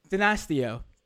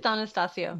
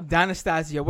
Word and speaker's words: D'Anastasio: Cecilia D'Anastasio. Did I say that right? I think I did not. – I think you did not. It's D'Anastasio. – D'Anastasio.